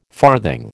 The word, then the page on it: farthing: American English